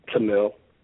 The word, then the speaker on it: Tamil: It's Tamal. It's pronounced T-U-M-I-L, but it's spelled T-A-M-I-L.